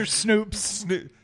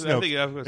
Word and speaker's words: Snopes: Snopes or Snoops? – Snopes. It is Snopes.